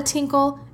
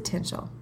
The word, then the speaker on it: tinchel: And she also said tinchel. So it's not a tinkle, it's a tinchel.